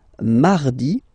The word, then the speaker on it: mardi: Then mardi, mardi. So you can hear that you don't pronounce it that strongly, this R. Hein? Mardi, mardi. Okay, you don't need to go too deep like mardi. No, no, no. You don't move your tongue, so it doesn't sound like mardi.